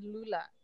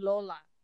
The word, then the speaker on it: Lola: We've always pronounced it Lulu and Lula. It's actually pronounced Lolo and Lola.